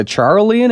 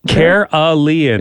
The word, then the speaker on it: Carolean: Are you are you saying that right? Wouldn't it be like the Charlean area? Carolean. Is there an H in there? Here's the the phonetic spelling. – Uh-huh. – Carolean. C A R O L E A N.